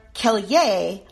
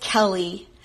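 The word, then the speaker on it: Kellye: When people see the spelling of my first name with that unusual E sort of sticking out at the end, they sometimes think my name is pronounced Kelly or Kelye like Kanye. But it is really just Kelly.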